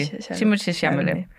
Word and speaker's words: Timothée Chalamet: Mm, ja, som er sådan, hans navn bliver udtalt, nemlig. Timothée Chalamet.